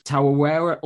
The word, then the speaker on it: Tarawera: You've got the UTMB, CCC, the Trail Running World Championships, Western States 100, Tarawera Ultra, is it? I don't know too much about that one. – Good pronunciation, yeah.